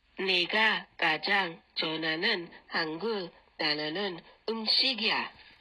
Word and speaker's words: 좋아하는: 좋아. Is a 히읗 받침 and then an a sound as a second syllable. So 좋아하는, 좋아하는.